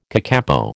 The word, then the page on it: kakapo: American English